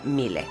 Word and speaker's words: Miele: Y es la marca alemana Miele, que seguramente la han visto, se escribe Miele, pero se pronuncia Miele.